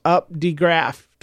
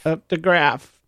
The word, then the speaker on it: Updegraff: Jane Updegraff is her name, and the last name is unique because it looks like Up Giraffe or something like that, but there's a D right in there, and she says it's pronounced exactly how it looks, Updegraff. – Updegraff.